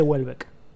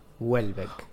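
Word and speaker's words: Houellebecq: Entonces lo busqué y eh, se dice Huelbeck. En francés se dice Huelbeck. – Welbeck. – Welbeck o Welbeck, pero creo que es Welbeck.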